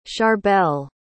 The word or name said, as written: charbel